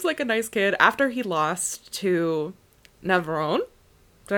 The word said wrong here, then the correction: Navone: Navone. – I think it's Navarone. – Navone. There's no R in that name.